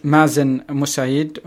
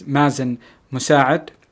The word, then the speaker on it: Mazen Musaid: My name is Mazen Musaid, and the Arabic pronunciation is Mazen Musaad.